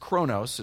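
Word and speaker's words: chronos (χρόνος): The first one is pronounced chronos.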